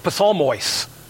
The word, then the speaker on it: psalmois: For example, it's psalmois. They pronounce the P, we don't, but psalmois.